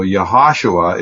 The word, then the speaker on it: Yehoshua: Okay, so Yehoshua is the proper pronunciation of the Hebrew form, but in the English Bible, that's transliterated as Joshua.